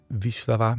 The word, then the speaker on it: Wisława: En Wisława met zo'n elletje met een streepje erdoor, dat spreek je volgens mij uit als een bijna als een W.